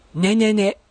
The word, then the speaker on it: ねねね: ねねね、出ました、またわけのわからない言葉、ねねねですけれども、アクセントは置かない。